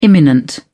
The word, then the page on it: imminent: British English